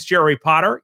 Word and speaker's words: Jerry Potter: My name is Jerry Potter. Yes, it rhymes with the boy wizard.